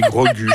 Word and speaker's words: Grogu: Moi, je dis Grogu, là, parce que ça m'amuse, mais oui, Grogu, je pense, ouais.